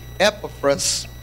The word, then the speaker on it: Epaphras: And in that revival in Ephesus was a young man, and I pronounce his name Epaphras.